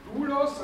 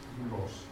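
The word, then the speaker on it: δοῦλος: Dann Dulos als nächstes beginnt mit einem Delta,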